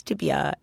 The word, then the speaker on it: tibia: Oh, well, I know so little about this that I actually realize I mispronounced tibia. I said tibula, so it's tibia and fibula.